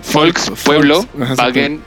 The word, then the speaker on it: Volkswagen: De hecho, lo dije mal, es Volkswagen, porque es Volkswagen es auto.